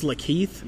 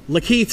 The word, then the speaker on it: Lakeith: How does Lakeith Stanfield pronounce his name? Is it Lakeith? – It's Lakeith? – Yes.